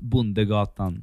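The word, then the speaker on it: Bondegatan: In Swedish it's pronounced Bundegatan.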